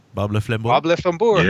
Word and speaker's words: Bob le flambeur: We'll be doing Bob Le Flambeur. Is that how you say it? Bob Le Flambeur.